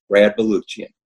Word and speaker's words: Baluchian: Hopefully I'm saying your name right. I usually check with our guests before, and I know in your book you even mentioned that your name gets butchered. So am I saying it right? – I butcher it. Yeah, it's Baluchian. Yeah, just pronounce all the letters. It's all there.